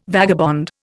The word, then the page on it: vagabond: Indian